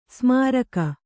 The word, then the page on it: ಸೋಮರಾಗ: sōma rāga